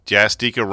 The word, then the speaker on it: Jastika: Jastika rhymes with Eureka. So I've been basically saying it right.